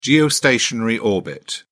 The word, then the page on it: geostationary orbit: British English